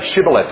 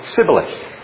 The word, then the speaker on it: Shibboleth: But those of the dialect from Ephraim couldn't say Shibboleth. They couldn't pronounce sh. They could only say ts. So they said Sibboleth.